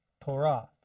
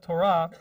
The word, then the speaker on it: Torah: For instance, the Bible word for the books of Moses is Torah. That's how you say it in Hebrew, Torah. But Yiddish, they take it and they switch the way the syllables are pronounced. So it becomes, instead of Torah, it becomes Torah.